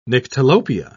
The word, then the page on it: nyctalopia: British English